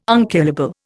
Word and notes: unkillable: Indian

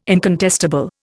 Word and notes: incontestable: Indian

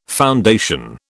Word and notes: foundation: British English

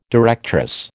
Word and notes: directress: American English

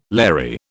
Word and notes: Larry: British English

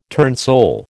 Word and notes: turnsole: American English

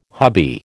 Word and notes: hubby: American English

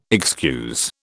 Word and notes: excuse: British English

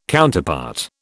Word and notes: Counterpart: British English

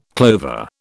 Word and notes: clover: British English

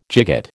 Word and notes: gigot: American English

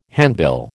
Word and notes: handbill: American English

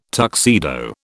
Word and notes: tuxedo: British English

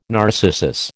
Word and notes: narcissus: American English

